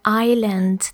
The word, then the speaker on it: island: That is an isle or an island. The S is not pronounced in English, but it's written.